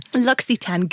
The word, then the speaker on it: L'Occitane: And Millie has just taught me how to pronounce L'Occitane. – L'Occitane, good accent. – Thank you. – Very good. It's like you're French.